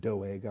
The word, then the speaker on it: Doeg: A maskil of David, when Doug the Edomite came and told Saul and said, behold, David has come to the house of Ahimelech. Remember Doug the Edomite? I probably should pronounce it Doeg.